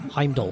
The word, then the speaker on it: Heimdall: He was cast as Heimdall. Am I saying it right? – Heimdall.